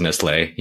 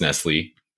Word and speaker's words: Nestlé: Yeah, so, well, I guess before I dive in, for our listeners that are in the United States, when Alberto says Nestlé, he means Nestle, even though he's right, and he's probably pronouncing it the proper way. Here in America, we're saying Nestle.